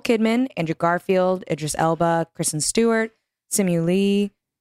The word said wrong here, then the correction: Liu: pronounced that wrong. Simu Liu, Benedict Cumberbatch, MJ Rodriguez, and Penelope Cruz.